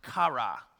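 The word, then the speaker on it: kara (χαρά): One of the predominant words that's used is this word that, that's pronounced kara. Say kara. Ooh, listen to that.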